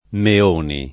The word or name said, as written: Meoni